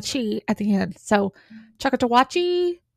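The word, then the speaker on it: Choctawhatchee: Brayer's company, usually described as the Dale County Home Guard near the Choctaw River. I don't think I'm saying that right. It's Choctawatchee River.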